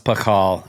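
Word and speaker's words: Pachal: And is it Pashal or Pakal? Because who is Pashal? – I've heard it pronounced Pakal.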